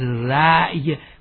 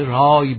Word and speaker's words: رای: یادمون باشه در فارسی هرگز رأی، خصوصا در شعر تلفظ نکنیم، در شعر همیشه رای باید بگوییم.